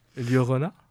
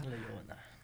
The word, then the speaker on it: Llorona: Llorona, c'est ça ? – C'est ça. – Llorona.